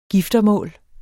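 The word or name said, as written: giftemål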